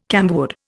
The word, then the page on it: camwood: Indian